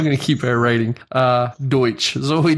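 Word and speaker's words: Deutch: Is that how you say her name? – Deutsch. – That makes much more sense than Deutsch.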